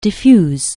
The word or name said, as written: diffuse